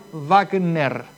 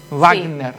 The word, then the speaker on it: Wagener: Mira, acá lo que me dice Inteligencia Artificial, la pronunciación correcta de Wagener, o sea, W-A-G-E-N-E-R, de origen alemán, se aproxima fonéticamente a Wagner. Wagner.